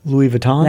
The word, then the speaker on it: Louis Vuitton: Louis Vuitton? – That's what it okay. – is. Mm-hmm. And you pronounced it correctly.